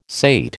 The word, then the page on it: sate: American English